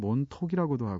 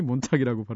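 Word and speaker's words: Montauk: 몬탁 스펠링이 굉장히 독특해요. M-O-N-T-A-U-K라서 어떤 사람은 몬톡이라고도 하고 몬타우크라고도 하는데 현지에서 제가 정확히 물어봤습니다. 정확히 몬탁이라고 발음하고요.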